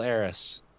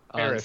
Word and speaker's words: Aerith: Do you think they're gonna kill Eris? – Aerith.